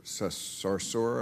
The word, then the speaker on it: Sarsour: I know you were having a bit of a Twitter spat sometimes with this woman, Linda Sarsour.